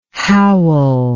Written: howel

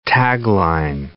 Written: tagline

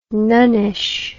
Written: nunnish

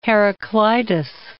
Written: Heraclitus

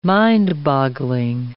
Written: mind-boggling

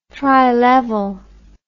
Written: trilevel

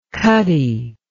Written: cutty

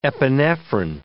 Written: epinephrin

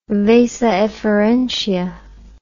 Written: vasa efferentia

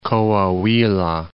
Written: Coahuila

